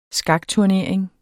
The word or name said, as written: skakturnering